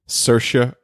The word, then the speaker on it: Saoirse: Wait, say her name again. – Saoirse. Is it? Oh, I say Saoirse. – That's okay. – Saoirse Ronan. – There you go.